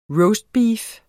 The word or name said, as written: ROASTBEEF